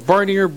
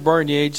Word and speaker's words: Barnier: Either way, Barnier, Barnier, depends on how much French you speak.